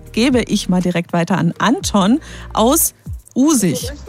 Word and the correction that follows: Usingen: Habe ich das richtig gesagt? – Aus Usingen, genau.